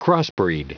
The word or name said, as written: crossbreed